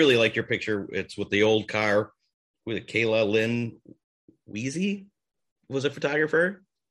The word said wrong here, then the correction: Weiss: I think it's Weiss. I think it's Weiss. Just Weiss.